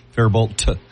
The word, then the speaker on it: Faribault: It's Faribault spelled out. Faribault, not pronounced that way, but spelled that way, right? – I like the way you think, though.